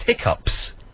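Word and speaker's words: hiccough: This is how it's spelt. And people that say hiccups, but it's pronounced hiccups, even though it's spelled hiccoughs.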